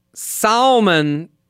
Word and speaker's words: salmon: He pronounces the L in salmon and almond. – You just have bad phonics. – Almond makes more sense with the L. Salmon makes no sense.